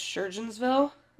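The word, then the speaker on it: Surgoinsville: and so his parents decided to sell their house in Knoxville and they wanted to retire to, I think it's pronounced Surgeonsville.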